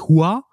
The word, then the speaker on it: Hua: Ähm, da habe ich, da ich was ganz Schönes zu gelesen und zwar, dass äh, dieses Fa Mulan ähm, eigentlich die, die kantonesische Aussprache ihres Namens wäre, was aber falsch ist, weil im Mandarin wäre es halt wirklich Hua also mhm. H-U-A quasi in der, in der Lautschrift, was so viel mhm.